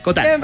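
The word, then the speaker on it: Cotton: Marianne Cotán, porque no estaba seguro si se pronunciaba Cotín, pero Cotán. – Francés, Cotán.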